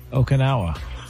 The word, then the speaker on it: Okinawa: How you say it? I mean, how are you supposed to say it? Okinawa? – Okinawa.